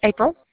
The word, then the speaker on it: April: April. – Can you spell it? – A-P-R-I-L. – A-P-R-I-L.